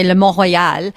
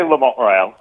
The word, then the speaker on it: Le Mont Royal: So we pronounce it, obviously, we're going to say Le Mont Royal, but how are Americans saying the name of your restaurant? – Le Mont Royal.